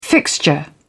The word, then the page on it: fixture: British English